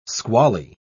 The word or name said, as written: squally